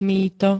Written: mýto

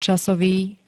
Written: casovy

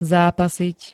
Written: zápasiť

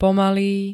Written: pomalý

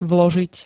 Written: vložiť

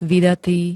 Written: vydatý

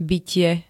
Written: bytie

